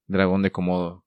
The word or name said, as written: Dragon de Komodo